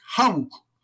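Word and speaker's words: Houck: Mark Hauck, se pronuncia hauck padre de siete hijos y pacífico consejero provida frente a centros abortivos.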